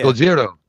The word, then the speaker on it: Gojira: Are you talking about the actual Japanese pronunciation of Godzilla? – Yeah. – Gojira.